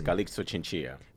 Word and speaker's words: Calixto Chinchilla: In Spanish, it's Calixto Chinchilla.